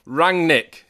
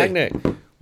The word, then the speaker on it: Rangnick: Rangnick. Easy. – Rangnick.